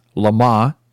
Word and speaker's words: Le Mans: Le Mans, Lamar, as they pronounce it in the movie, Steve McQueen.